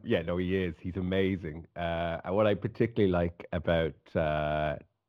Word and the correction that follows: Takumi: is that, am I pronouncing that correctly? – Takumi. – Let's go. Yeah, Takumi.